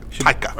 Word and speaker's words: Taika: Taika spelled like Tyga, but different. You pop the A round at the end. Get rid of the G. – Taika.